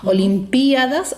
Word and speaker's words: olimpiadas: Olimpiadas o olimpiadas. En el caso de la pronunciación olimpiadas, ¿verdad?, que sería una pronunciación llana, no lleva tilde. Y en el caso de olimpiada, sí lleva tilde en la i.